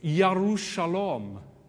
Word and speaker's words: Yerushalayim: Jerusalem actually is Yerushalom. That's probably appalling pronunciation.